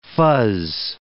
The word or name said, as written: fuzz